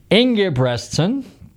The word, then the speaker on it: Ingebretson: Scott, let's see if I pronounce this right, Ingebretson.